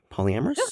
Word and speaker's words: polyamorous: And I'm so glad we got to talk about being, I'm going to pronounce this wrong, polyamorous? Polyamorous? – Yeah, that's totally right, Polyamorous.